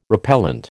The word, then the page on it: repellent: American English